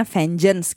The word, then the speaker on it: VNGNC: VNGNC itu screen name-nya lah. Jadi maksudnya vengeance gitu dibacanya alias dendam.